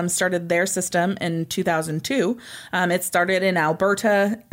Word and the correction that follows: Alberta: I don't know why I just said Alberta that way. – Wow. All the emphasis on the T. – I was super weird. – Started in Alberta.